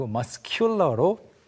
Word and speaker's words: muscular: 여기서는 u가 뒤에 따라오면서 살짝 스펠링이 바뀌어서 muscular가 아니고 muscular로 k 발음이 살아납니다.